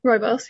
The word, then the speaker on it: Rooibos: I think I'm saying this right, Robos. – Rooibos, yeah.